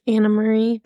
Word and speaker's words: Anna Marie: But at least like Amory or Anna Marie even though it's spelled like Anne Marie. It's Anna Marie.